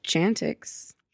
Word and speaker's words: Chantix: It's just Chantix.